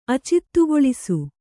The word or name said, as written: ಅಚಿತ್ತುಗೊಳಿಸು